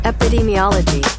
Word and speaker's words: epidemiology: Epidemiology.